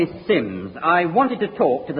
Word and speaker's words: Simms: It's spelled S-I-M-M-S, but pronounced Sims.